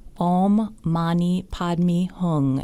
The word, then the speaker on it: Om Mani Padme Hum: And the words are, the way we all pronounce it, are Om Mani Padme Hum. Om Mani Padme Hum.